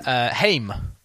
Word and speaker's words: Haim: But it's taken from their surname, which is Haim, spelt H-A-I-M. And I, up until recently, been pronouncing it Haim, as in H-A-I-M. And I thought that was the correct way. And then Wikipedia said, no, it's Haim, as in time.